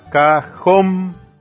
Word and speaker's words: cajón: Cajón, la siguiente palabra, cajón, se carga la voz en la última sílaba.